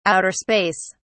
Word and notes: outer space: British English

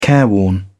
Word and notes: careworn: British English

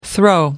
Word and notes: throw: American English

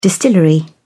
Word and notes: distillery: British English

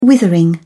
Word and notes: withering: British English

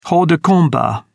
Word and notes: hors de combat: British English

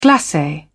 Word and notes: glace: British English

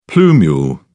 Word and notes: plumule: British English

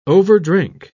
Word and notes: overdrink: British English

